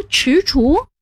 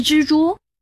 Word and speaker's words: 踟蹰: 那两个字读踟蹰，不是蜘蛛。 – 踟，左边是足字旁，右边是知道的知，也难怪你读作知，正确读音是踟。